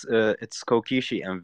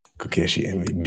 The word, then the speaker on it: KokeshiMV: How do I pronounce the project name? KokeshiMV? – Hello. – Is that right? – Yeah, it's KokeshiMV, yeah. – KokeshiMV.